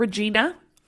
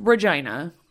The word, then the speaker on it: Regina: Now, for those of you that are not familiar with Regina, Saskatchewan, yes, that is how you say it. It's not Regina, as some people say. No, it's pronounced Regina.